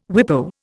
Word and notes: WIPO: Indian

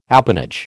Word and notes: appanage: American English

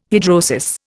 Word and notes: hidrosis: Indian

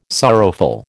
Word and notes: sorrowful: American English

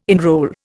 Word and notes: enrol: Indian

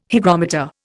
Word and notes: hygrometer: Indian

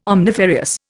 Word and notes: omnifarious: Indian

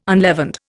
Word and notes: unleavened: Indian

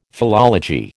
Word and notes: philology: American English

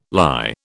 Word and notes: lye: British English

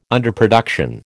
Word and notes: underproduction: American English

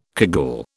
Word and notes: cagoule: British English

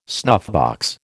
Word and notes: snuffbox: American English

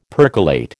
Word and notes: percolate: American English

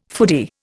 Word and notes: foodie: Indian

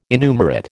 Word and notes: innumerate: American English

